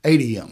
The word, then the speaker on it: ADM: Another one is, and I'll get the acronym wrong, is the ATM, the grain elevators. Am I saying that right? – ADM.